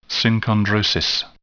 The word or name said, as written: synchondrosis